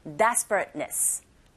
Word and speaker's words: desperateness: 요거 발음하실 때 앞부분에 광세 주시고 뒷부분에 한번 끊기면서 ness 하셔야 되거든요. desperateness. 이렇게 하시면 됩니다.